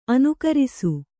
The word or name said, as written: ಅನುಕರಿಸು